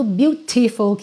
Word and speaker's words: beautiful: Então, por exemplo, beautiful, que vira beautiful, tá? Esse T virou rarará. Então, sempre que isso acontece, eu já pinto essa letra de azul para você já sacar que vai ser essa pronúncia, que não é obrigatória, tá bom? Ela só é muito comum e é bacana você treinar o seu ouvido para você não estar tá esperando ouvir beautiful e a pessoa falar beautiful, beautiful, porque dá uma outra dinâmica, a palavra fica mais rápida, certo?